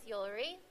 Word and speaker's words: Yulery: My name is Yulery. – What? Your name's Yulery? – Yeah, rhymes with jewelry.